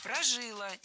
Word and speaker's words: прожила: Прожила. Неверно. Правильный вариант ударения – прожила. Ударяем последний слог. Окончание. Кстати, в подобных сложных глаголах женского рода чаще всего ударение падает именно на окончание.